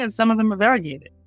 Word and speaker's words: variegation: So I think, to Rachel's point, the trends, the variegated, am I saying that right? – Variegation. – Variegation. Okay.